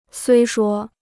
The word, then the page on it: 虽说: suī shuō